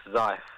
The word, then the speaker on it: Zeiss: Yes, Zeiss. Yeah. – And is that like something that you can, I don't know, what can you see through the Zeiss telescope? How far can you view? So, Zeiss is our big telescope.